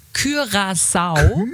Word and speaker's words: Curaçao: Also richtig wäre Curaçao. Und hier bitte eure herrlichen Vorschläge. – Curaçao.